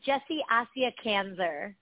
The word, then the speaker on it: Jessie Asya Kazner: So it's Jessie Asya Kazner.